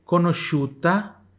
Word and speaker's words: conosciuta: Siena é, mo Siena é molto, atenção para o som do L, conosciuta tá? O SCI tem som de X.